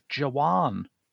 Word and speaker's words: Jawan: September saw the release of Jawan. – I believe it's pronounced with a W, which means soldier in Hindi.